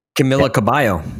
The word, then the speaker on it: Cabello: Camila Cabello.